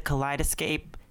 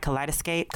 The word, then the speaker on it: kaleidoscope: Yeah, I love that, especially with the Kaleidoscape. Did I say that right? Kaleidoscope.